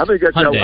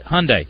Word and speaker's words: Hyundai: It's like when you're talking to your wife, you say, hun, and then you say, what day is it? Hun, what day is it? Hyundai. Simple.